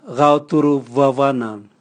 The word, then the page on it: gao turuvwavwana: Raga